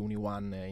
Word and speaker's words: UniOne: dato che appunto letteralmente UniOne in italiano si legge unione.